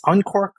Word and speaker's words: Uncork: It's Uncork, like the uncorking a bottle of wine.